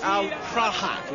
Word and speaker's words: Prahat: Yes, look, actually, the correct pronunciation is, in fact, Al Prahat, you see? Prahat, at the back of the throat. – Truly you are a Pratt! – No, Prahat. It needs to be more Arabic.